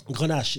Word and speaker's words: Grenache: Is that how I pronounce that? Vine Grenache. Is that right? – Grenache, yes.